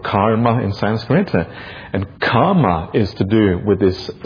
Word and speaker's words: kama: There's a slight different in pronunciation that is often missed by people who don't have an Indian background. And the kamma is a double M, and it's pronounced as if kamma, twice. Kama is a long A sound, and it's different.